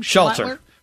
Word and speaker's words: Schlatter: Is that how you pronounce his name? – Schlatter. – Schlatter? Like Schlatter. Give me Schlatter.